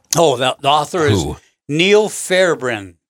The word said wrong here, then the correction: Fairbairn: Do I pronounce that right? Or you try. – Who knows? – Yeah. – Fairbairn. Neil Fairbairn, A Brief History of Gardening.